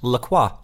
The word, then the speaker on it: LaCroix: Oddly enough, so I also thought that LaCroix was pronounced LaCroix because it's got all of those extra letters in it, so I figured that it was French. But in fact, LaCroix is made in America by the St. Croix River, which is spelled C-R-O-I-X, and is pronounced Croix. So, yep, it's American, it's not French. And they say on the website, it says right there, it's pronounced LaCroix, like enjoy, apparently.